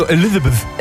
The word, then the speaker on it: Elizabeth: Ja, aber Elisabeth hast du gesagt. – Achso, Elizabeth.